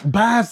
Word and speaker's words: Basyl: Not BASYL, the thing. Like, oh, wait. Bass, the fish.